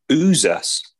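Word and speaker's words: Uxas: So the first one is the backstory of seeing the big battle and it kicking off with Uaxis. Uaxis? – Mm-hmm. I think it's Uzas because it's X is a Z. It pronounced in a word. I'm sure it is. It's U-Z. It'd read U-Z-A-S, so Uzas.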